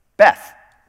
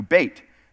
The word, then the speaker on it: Beit: Beth, or as it would be pronounced in Hebrew, Beit.